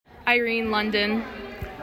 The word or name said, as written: Irene London